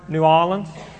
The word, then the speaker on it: New Orleans: And what I'd like for us to consider as an allegory once again is The city of New Orleans, or New Orleans, that's how you pronounce it once you've lived in Louisiana for a while.